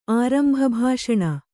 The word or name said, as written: ಆರಂಭಭಾಷಣ